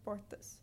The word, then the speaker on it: portas: And in Portuguese, the word that people always use to exhibit this accent is the word doors, portas, P-O-R-T-A-S. So a person from São Paulo would probably pronounce it like portas, but a person from Rio de Janeiro would pronounce it like portas. So we see this very aspirated, almost H-sounding R, and then the S turns into an S-H.